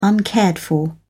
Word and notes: uncared for: British English

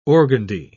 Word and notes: organdy: British English

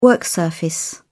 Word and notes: work surface: British English

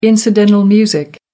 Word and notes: incidental music: American English

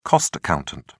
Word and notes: cost accountant: British English

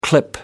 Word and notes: clip: American English

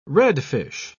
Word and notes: redfish: British English

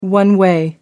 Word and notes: one-way: American English

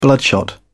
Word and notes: bloodshot: British English

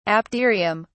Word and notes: apterium: British English

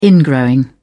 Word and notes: ingrowing: British English

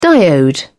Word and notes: diode: British English

